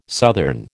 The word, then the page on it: southern: American English